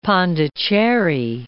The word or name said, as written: Pondicherry